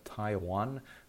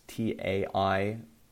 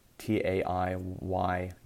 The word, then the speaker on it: Taiyuan: I think it's pronounced Taiwan, not like the country, but it's Taiyuan. Taiyuan.